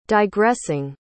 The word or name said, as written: digressing